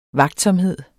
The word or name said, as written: vagtsomhed